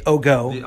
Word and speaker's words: OGO: Did I say that right? – The OGO. – I'm sorry, the OGO.